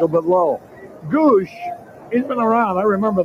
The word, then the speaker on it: Gwosdz: Now I want to ask you about wouldn't you rather have this name spelled G O O S H the phonetic spelling rather than all the S's, W's, and Z's. – Gives him some character, though. G-W-O-S-D-Z is the spelling, but it's pronounced Goosh.